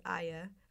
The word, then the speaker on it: Hagia: Another one that I didn't know was it's spelled H-A-G-I-A, but it seems online it's pronounced Hagia Sophia Cathedral in Istanbul, Turkey.